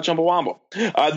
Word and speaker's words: Chumbawamba: It's not Chumbawamba. It's Chumbawamba. Chumbawamba, not Chumbawamba, as it's frequently mispronounced.